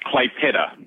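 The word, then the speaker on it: Klaipėda: Quite, uh, had to wait until I got there to work out how to actually pronounce it correctly, as per the Lithuanians pronounce it, but it's pronounced Klaipeda.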